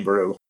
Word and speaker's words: Boru: And Baru is spelled B-O-R-U. – Yeah, Brian Baru.